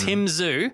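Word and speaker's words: Tszyu: Okay, admittedly, it's not spelt like Z-W-O, but Tim Zhu is the boxer. So it's Tim Zhu.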